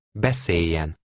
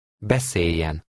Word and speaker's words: Beszéljen: In this word, you see the letters L and J together. This is another case of consonant assimilation, and here the L is silent. Listen and repeat number 11. – Beszéljen. Beszéljen.